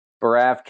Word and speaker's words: Baraff: Is it Braff, just like giraffe, but with a B? – Baraff, giraffe, Baraff, carafe, but it can also be Baraff.